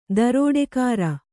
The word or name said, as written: ದರೋಡೆಖೋರ